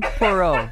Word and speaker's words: Poirot: Hercule Poirot.